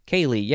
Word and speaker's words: ceilidh: Next, we will see ceiling shortly, but first we must talk about this fun word, which is pronounced Kaylee? Kaylee, yes. But it is spelled two ways. C-E-I-L-I-D-H.